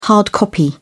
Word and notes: hard copy: British English

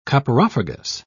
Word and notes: coprophagous: British English

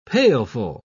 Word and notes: pailful: British English

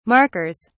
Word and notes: markers: British English

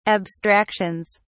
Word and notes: abstractions: British English

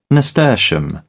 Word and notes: nasturtium: British English

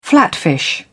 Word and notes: flatfish: British English

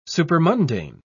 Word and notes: supermundane: British English

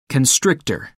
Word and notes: constrictor: American English

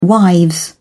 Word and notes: wives: British English

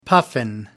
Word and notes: puffin: American English

